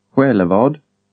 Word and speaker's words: Själevad: Consonant groups like SJ. Repeat the following words containing the consonant groups, which are all pronounced the same as SJ, or soft SK. SJ, place names. – Sjukaby. Själevad.